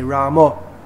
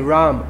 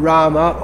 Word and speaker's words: Ram: They say Ramo, Hare Ramo. In the Hindi speaking area, they'll just say Ram. In Gujarati also, Ram. It's not Rama or Ramo. It's Ram.